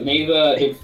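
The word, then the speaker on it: Nezha: So I think it's pronounced Neza. It's N-E-Z-H-A, I believe. – That's it. So Neza, it's New God, Neza Reborn, and seriously, it's not Japanese animation, it's Chinese animation.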